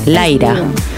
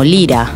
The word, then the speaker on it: Lyra: Y me gusta cómo se pronuncia en inglés, Lyra. Porque en mi país, Ucrania, se pronuncia como Lira.